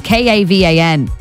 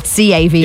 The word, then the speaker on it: Cavan: It's Kavan, not Cavan. – Yeah. Not like the county.